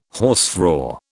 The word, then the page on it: hausfrau: British English